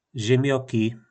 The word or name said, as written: Zymjoki